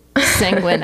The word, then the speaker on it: sanguine: Like sanguine actually. Actually, I got that one from a podcast. – But rhymes with penguin.